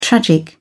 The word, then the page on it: tragic: British English